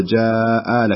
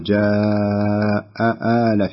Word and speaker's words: جاء آل: يعني بعض الناس يخطئ في نطق الهمزتين المفتوحتين بعضهم يقول ولقد جاء آل فرعون يعني مع السرعة أو لكن ما شاء الله هي حققتها بطريقة جميلة جدا ولقد جاء آل فرعون النذر بارك الله فيك يا أم علي